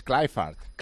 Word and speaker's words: Kluivert: Y Kluivert es Clifford.